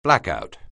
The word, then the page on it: blackout: anglais